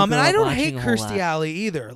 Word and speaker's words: Kirstie Alley: And like Lee just was mentioning earlier, the person who plays his love interest and his wife is Kirstie Alley. – I think it's Kirstie Alley. – Kirstie Alley. Yep, I knew I was saying it wrong.